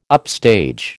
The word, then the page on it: upstage: American English